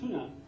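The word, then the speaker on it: Tønder: It's pronounced it tuna. Called T-O-N-D-E-R, with a funny kind of Danish O with a left slash to it. The tuna.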